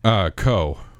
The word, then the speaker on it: Koch: Quickly, though, is it Co. or is it Koch or is it Koch? How do you pronounce K-O-C-H? – Co. – That is Co. in my neighborhood.